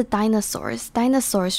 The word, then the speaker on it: dinosaurs: 接下来是 dinosaurs 就是恐龙，应该是一个蛮基本的字，但它的发音常常大家讲的不是那么好。前面的 DI 是长的母音 ,die 的感觉。